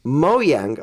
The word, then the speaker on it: Mojang: Yesterday, Mojang, or what was the new pronunciation for Mojang? – It's that we Mojang. Discovered? – Mojang, okay. – Yeah, we found that out at the Microsoft press conference.